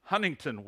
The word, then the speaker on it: Huntington: And now I am the chief discipler of our Wilkinson Street campus in Huntington, West Virginia. I'm sorry, I said that wrong. I said it with my Illinois accent. It's Huntington, West Virginia.